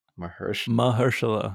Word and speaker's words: Mahershala: Mahershala. – Okay. I'm going to get that one day. – It ain't that hard, dude. – It is. Mahershala.